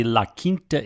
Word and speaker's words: La Quinta: So I taped last week's show from the La Quinta Inn in Salisbury, Maryland.